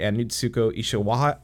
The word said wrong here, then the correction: Ishikawa: uh, Ishikewa. All right, I'm butchering his last name. Natsuko Ishikawa. There we go.